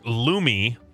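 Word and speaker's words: Lume: yeah, it's pronounced Lumi. Lumi, it's L-U-M-E.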